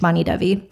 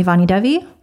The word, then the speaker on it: Vani Devi: Vani Devi. So when I'm at the ashram, when I'm in spiritual communities, people call me Vani Devi. It's spelled V-A-N-I space D-E-V-I.